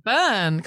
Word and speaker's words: Byrne: Byrne, come on.